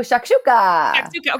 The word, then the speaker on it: shakshuka: But okay, I'm probably going to pronounce it wrong, but shashuka? – Oh, shakshuka.